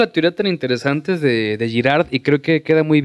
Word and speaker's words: Girard: Es la, la, teoría de René eh, Girard, espero estar pronunciándolo bien. Es la, Girard, Girard.